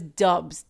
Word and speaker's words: Doubs: Is it Dubs or Dubs? Dubs? Dubs. – It's spelled D-O-U-B-S. – I know. I'm literally looking it up right now and seeing Dubs? How it's pronounced to make sure. He's always referred to as Dubs. D-U-B-S is how they like kind of say it.